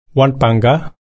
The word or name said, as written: warnpangga